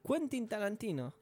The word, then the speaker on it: Quentin Tarantino: Porque me, gustó cómo lo pronunciaste. Quentin Tarantino.